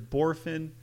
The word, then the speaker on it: Bohrofen: Bohrofen, I've heard, but I'm pretty sure it is Bohrofen. Pretty straight, almost two syllables, closer to three.